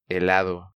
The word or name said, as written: Helado